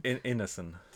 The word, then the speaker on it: Ineson: Ineson.